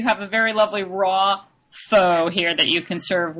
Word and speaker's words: pho: Pho, which I pronounce pho, but I've learned it's pronounced pho.